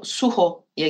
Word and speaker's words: Sujo: Evet, Dünya Sineması büyük ödül, büyük jüri ödülü Sujo, Suho'ya gitmiş. Meksika filmi olduğuna göre herhalde öyle okunuyordur.